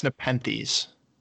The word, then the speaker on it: Nepenthes: Is it Nepenthes or Nepenthes? – I think it's Nepenthes.